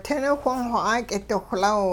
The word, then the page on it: ting niwhong-xw 'aak'idiwhlaw: Hupa